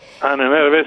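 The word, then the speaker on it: Ahnenerbe: No sé si lo voy a pronunciar bien, la Annette Berbe. – Annette Berbe, sí, sí, efectivamente.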